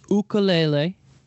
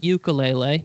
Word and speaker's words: ukulele: Took a ukulele lesson. Excuse me. Sorry. I mispronounced that at first. It's ukulele, not ukulele. Another common misconception.